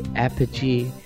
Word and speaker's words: apogee: You could say that I am at the apogee of my smoking experience. – And how would one spell apogee? – A-P-O-G-E-E.